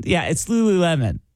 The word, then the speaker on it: Lululemon: Lululemon, is that how you pronounce it? Lululemon? Yeah, it's Lululemon.